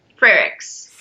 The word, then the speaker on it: Frerichs: And Courtney, I'm going to say your last name wrong, Frerichs? – Frerichs. – Frerichs. – Yeah.